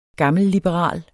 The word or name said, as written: gammelliberal